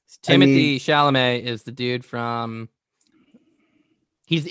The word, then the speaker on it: Chalamet: This 2018 film starring Steve Carell and Timothy Chalamet portrays the relationship of a father.